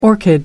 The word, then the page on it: orchid: American English